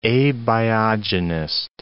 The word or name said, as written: abiogenist